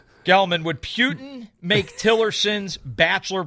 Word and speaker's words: Putin: There's no P-Y-U. – I'm refuting for Putin, okay? – Putin, as in P-O-O.